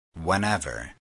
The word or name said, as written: whenever